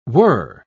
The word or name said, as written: were